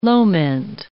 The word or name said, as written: loment